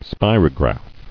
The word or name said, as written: Spirograph